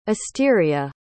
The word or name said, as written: asteria